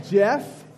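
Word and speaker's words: Geoff: My name is Jeff. – Hi, Jeff. – Hi. And I spell it with a G, which is weird.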